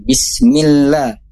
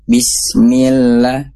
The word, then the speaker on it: bismillah: Termasuk bismillah, bismil, itu langsung aja dimasukkan gitu ya, tanpa ada jeda, tanpa ada waktu. Setelah kasroh mi langsung ke lam, bismillah. Nah, yang berikutnya adalah kalau Bunda Entin ya bacanya itu kayak ada jeda, bismillah, bismillah gitu.